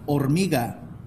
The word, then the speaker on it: Hormiga: Have you guys ever heard of the Volkswagen, the Hormiga? – No, now I've got to look that up.